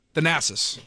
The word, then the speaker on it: Thanasis: Thanasis.